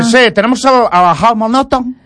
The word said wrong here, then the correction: Helmut Newton: Helmut Newton.